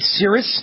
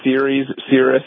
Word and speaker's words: Ceres: They're called Ceres. Is that how I pronounce it? Ceres? – Yeah, I think it's Ceres, yes.